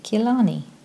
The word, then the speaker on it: Gilani: Gilani is my family name, and it's pronounced with a G sound, not a J sound. So it's Forcia Gilani.